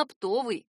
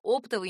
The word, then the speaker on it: оптовый: Именно оптовый. Орфоэпические словари специально отмечают оптовый неправильно. Эта ошибка возникает из-за влияния существительного и наречия «опт» и «оптом». Действительно, в них ударение падает на первый слог, но в производном прилагательном оно смещается на второй, а в однокоренном существительном — оптовик, и вовсе на третий.